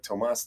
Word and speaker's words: Tomas: So my name is Tomas Bogardus, spelled like Tomas, T-O-M-A-S.